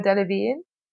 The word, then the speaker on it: Delevingne: I actually had a phase at uni then that everyone would call me Cara Delevingne.